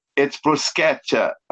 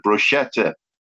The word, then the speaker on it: bruschetta: My neighbors are Italian of Italian relatives by marriage, so it's bruschetta, but loads of people pronounce it bruschetta.